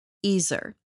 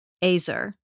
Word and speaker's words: ezer: Now, the English word helper or helpmeet in Genesis 2:18 is translated from the Hebrew word ezer. Some people pronounce it azer. Doesn't matter. E Z E R.